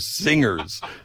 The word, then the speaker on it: singers: They're singers. Singers. But the G is silent. The G, there's, it's not a consonant.